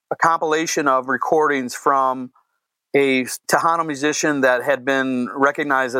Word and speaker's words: Tejano: Tejano music. I'm probably saying it wrong. – No, that's it. Yep.